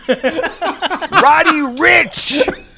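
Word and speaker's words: Roddy Ricch: Rich, Rich, Roddy Rich. – It's Roddy Rich.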